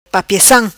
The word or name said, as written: Papiacam